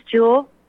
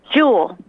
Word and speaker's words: Jewel: My name's Jewel. – Spell it. – Oh, I'm not on Facebook. Jewel. – J-E-W-E-L.